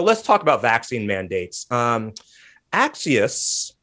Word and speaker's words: Axios: he said, nope, we say it like Axios.